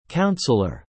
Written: Counselor

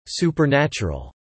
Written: supernatural

